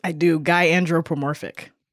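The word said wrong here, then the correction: gynandromorphic: Oh, no, there's no pro. It's guy andromorphic.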